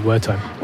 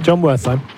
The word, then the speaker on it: Wertheim: The Brits will call him Wertheim. – Well, it's not the Brit; it's his name. – John Wertheim.